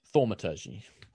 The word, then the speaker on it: Thaumaturgy: Thaumaturgy.